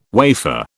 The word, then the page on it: wafer: British English